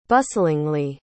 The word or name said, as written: bustlingly